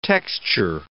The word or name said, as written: texture